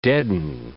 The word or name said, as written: deaden